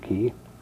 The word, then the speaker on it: Key: Yes, my name is Donald Key, pronounced just like it's spelled, K-E-Y, Key.